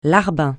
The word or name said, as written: larbin